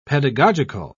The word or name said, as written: pedagogical